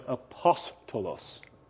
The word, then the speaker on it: apostolos: And it would be pronounced, by the way, apostolos.